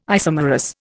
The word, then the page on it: isomerous: Indian